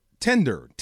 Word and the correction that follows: Tinder: tender. I'm s- tender, nope. – tender, tender steak, tender t- the app. Tinder. What? – Ten. Can you say ten? – Ten.